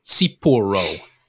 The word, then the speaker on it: tsipouro: So this is Cipro, and it's spelled T-S-I-P-O-U-R-O. Cipro.